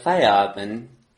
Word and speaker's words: Feierabend: We Feierabend.